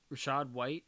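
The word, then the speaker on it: Rachaad White: Peter has Rashad White.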